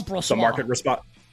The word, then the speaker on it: Brossoit: It's going to be their backup, what, Brossoit, or however I pronounce that guy's name. – Yep, that's Brossoit.